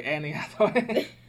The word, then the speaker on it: Anne Hathaway: Oh wait, Annie Hathaway. – Right, Anne Hathaway. You've all been saying my name wrong, she says.